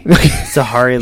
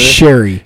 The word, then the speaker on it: Sherry: Why is it a long A? You fucking American. – Sahari. – Harry. Read. – Sari? – Sherry. – Sherry. Okay. S-H makes a sh sound.